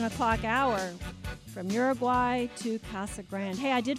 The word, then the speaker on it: Casa Grande: So she said the actual official Chamber of Commerce stand is that it's Casa Grande. That's the Spanish.